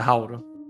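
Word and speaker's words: Rauru: Eu falo Rauro. Rauro. Rauro. – Vamos fechar assim, né? A pronúncia, Rauro.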